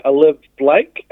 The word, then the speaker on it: Olivie Blake: It is called The Atlas Six by Olive Blake. It's an interesting spelling. It's a pseudonym. So it's spelled Olivie Blake.